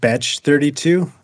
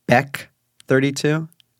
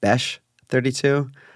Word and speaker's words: Bech32: It's pronounced Betch 32, and I will die on this hill. – Whoa, okay. And I'm going to come, what's it called, steel man this for you. It's Beck 32, not Besh 32.